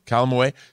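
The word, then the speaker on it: Calamawy: I know everyone is talking about Oscar Isaac, but can we talk about May Calame? Is that how you pronounce it? – Calamaway.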